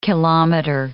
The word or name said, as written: kilometer